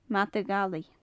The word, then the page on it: madaidh allaidh: gaelic